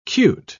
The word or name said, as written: cute